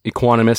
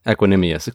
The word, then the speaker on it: Equanimous: On the outside, they do lose Equanimous St. Brown. I think I mispronounced that first name, but I gave it a shot. – Equanimous, excuse me.